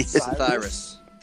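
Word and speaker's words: Psithyrus: Sithyrus. The P is silent. Sithyrus. It's like the Sith.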